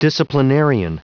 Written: disciplinarian

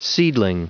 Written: seedling

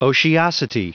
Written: otiosity

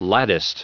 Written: latticed

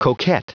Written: coquet

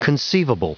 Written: conceivable

